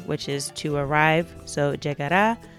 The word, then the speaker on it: llegará: So for example, it starts out, pronto llegará. So that llegar is followed by the a sound at the end, and that's where the emphasis is.